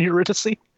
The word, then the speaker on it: Eurydice: Eurydice, which is the Matrix character. – I think the way you should remember this, if you ever forget it, is you think of Morpheus and Andrew Dice Clay.